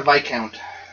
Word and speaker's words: viscount: it says chieftain, but you're somewhere between a count and a viscount. – So somewhere count or what? Subcount? – Somewhere between a count, or it's called a viscount. It's spelled V-I-S count. – V-I-S count.